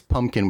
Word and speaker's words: Pumpkin: By the way, it's Pumpkin without a P, a second P that is, in case I'm saying it wrong sometimes.